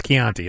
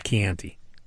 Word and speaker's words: Chianti: By the way, would this brilliant, genius guy really pronounce it Chianti? Or was he doing that on purpose? Because it's Chianti. It's not Chianti.